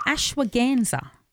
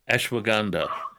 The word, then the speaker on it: Ashwagandha: You want to talk about a product called Ashwagandha. – Ashwagandha, I think. – Ganda, is it? – Yeah. I'm not sure. – Ashwagandha. – Yeah. – That's its correct pronunciation, ashwagandha.